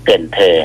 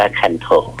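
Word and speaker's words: Cần Thơ: เกินเธอถ้าอ่านตามภาษาอังกฤษจะอ่านว่าคันโถอ่านตามภาษาอังกฤษจะอ่านว่าคันโถ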